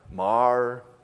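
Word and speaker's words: Maher: Maurer, is that how you say it? Mar, Bill M.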